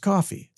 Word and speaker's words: Ko-fi: Or Ko-fi, I guess it's pronounced coffee, stupid.